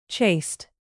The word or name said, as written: chaste